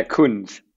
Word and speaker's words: Kunz: Yeah, Kunz.